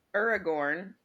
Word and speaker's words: Aragorn: I made the Aragorn.